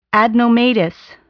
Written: adenomatous

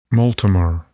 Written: multimer

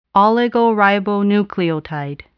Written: oligoribonucleotide

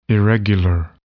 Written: irregular

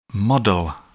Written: model

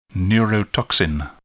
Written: neurotoxin

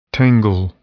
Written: tangle

